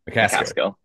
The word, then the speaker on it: McCaskill: And also former Houston running back Alton Mescal. I may have said that wrong. – McCaskill.